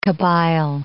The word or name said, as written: Kabyle